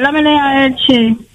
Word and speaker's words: Elche: E-L-C-H-E. Elche. C'est Elche, mais il faut dire Elche. Je ne comprends pas qu'on soit en train de dire. Alors, on est obligé de dire prononciation Elche.